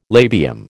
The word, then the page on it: labium: American English